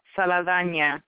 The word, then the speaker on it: Saldana: Saldana, because she's Dominican.